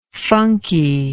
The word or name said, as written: funkiness